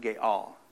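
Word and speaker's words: Ga'al (גאל): But this redeemer, I want you to understand that this is a big, the word is Ga'al, G-A, little mark up there, A-L. And I think it's pronounced Ga'al.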